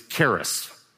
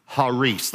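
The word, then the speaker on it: Haris (χάρις): Our English pronunciation of it is charis. And it's fine to say that, but that's really not how it's pronounced. The C in Greek is silent. And so this word is actually pronounced Haris. Haris. The I would be like a long E.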